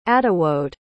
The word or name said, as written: attewode